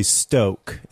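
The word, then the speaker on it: Stok: It's actually Stoke is how they want you to pronounce it.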